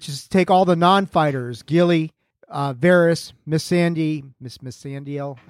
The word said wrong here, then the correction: Missandei: I don't know if I'm pronouncing it. – Even Missandei. Missandei.